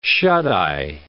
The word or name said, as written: shut-eye